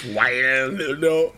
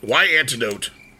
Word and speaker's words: Wyandotte: July 31st, 1863, Anderson's gang went to Wyandotte County, Kansas, or however you pronounce it. I probably did it wrong. – Yeah, it's Wyandotte. Yeah. Wyandotte.